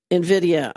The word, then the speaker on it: NVIDIA: NVIDIA. – NVIDIA.